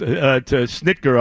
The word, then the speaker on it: Snitker: And by the way, watching the ninth inning of that game last night, Snedeker decides to Snitker, however you say his name, the manager for the Braves. – Snitker, yes.